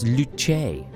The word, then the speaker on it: luce: He says the correct way to say it is not loose, it's luce. Of course, luce. Mamma mia.